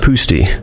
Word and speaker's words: Pusty: It's pronounced Pusty, All right. which is uh, Polish and confusing to a lot of people.